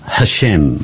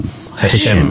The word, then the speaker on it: Hashem: It's not actually H-A-S-H-E-M or H-E-S-H-E-M. It's Hashem. – Hashem. Right. – Hashem. So it's a H, but the pronunciation of that H is given by the markings around it.